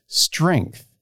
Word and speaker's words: strength: Strength sounds wrong because it sounds like ink, like it rhymes with ink, which is spelled with an I, but strength isn't spelled with an I, it's spelled with an E. And so when I say strength, to me, like I say it the way I would say wrench.